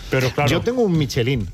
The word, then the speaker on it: Michelin: A ver, aquí en la Península Ibérica hacemos Michelin. – Sí, claro, pero la realidad, tú eres un chef internacional. – Ya, pero ¿tú cómo lo pronuncias? – Me cuesta ser tan tontorraco, ¿no? Dice Michelin. – ¿Michelin? – Yo digo Michelin.